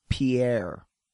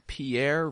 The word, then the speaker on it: Pierre: Most people think it's Pierre. – Oh. – And it's actually pronounced Pierre, South Dakota.